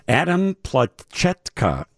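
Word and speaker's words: Adam Plachetka: Adam Platyka, actually, is the correct pronunciation of his name.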